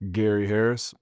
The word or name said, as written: Gary Harris